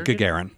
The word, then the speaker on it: Gagarin: The Gagarin. – The Gagarin.